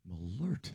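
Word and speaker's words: Malört: And this says it's pronounced Malort. – Malort. – There's an umlaut, the two dots over the O? This has a pronouncer saying Malort.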